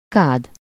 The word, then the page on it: bath: /bæθ/; /bɑːθ/